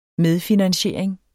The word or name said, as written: medfinansiering